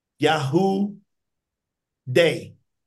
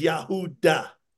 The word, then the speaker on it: Yahuda: Not one person will pronounce that Yahoo Day or Yahoo Dav or Yahoo Jah. Every single Jewish person says that's Yahuda.